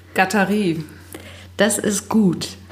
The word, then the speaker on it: Guattari: Die Amerikaner sprechen ihn Guattari aus. Aber er ist Franzose.